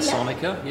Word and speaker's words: Ansonica: Is that, if I said that correctly? – Ansonica, yeah.